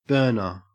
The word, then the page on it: burner: Australian English